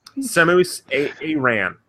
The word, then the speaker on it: Samus Aran: Samus Aran.